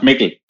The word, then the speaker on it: Mikkel: It's pronounced Mikkel. – Ah, okay. We did kind of pronounce it wrong.